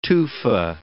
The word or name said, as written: tufa